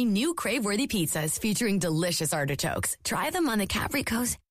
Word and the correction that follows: Capricciosa: Wait, how do you say this? – Capricciosa. It's capricciosa.